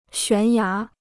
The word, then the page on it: 悬崖: xuán yá